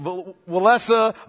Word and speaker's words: Walesa: You pronounce it Walesa. If I move between those, understand, it's a Polish name.